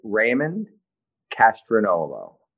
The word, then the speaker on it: Raymond Castronovo: It's said Raymond Castronovo.